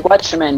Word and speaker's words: Watchmen: Sí, sí, está bien, de Watchmen, los Vigilantes.